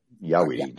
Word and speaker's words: Jaweede: But, you know, Yah is yes in German. Weed, of course, is weed. And then D-E, because it's spelled J-A-W-E-E-D-E, capital D and E.